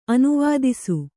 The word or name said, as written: ಅನುವಿಧಿಸು